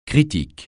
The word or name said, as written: critique